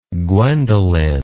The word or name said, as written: gwendolyn